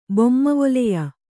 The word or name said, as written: ಬೊಮ್ಮವೊಲೆಯ